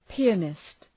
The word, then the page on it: pianist: pi:’ænıst